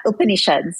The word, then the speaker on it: Upanishad: I want to make sure that I'm saying this right. Upanishad? – Yeah, Upanishads.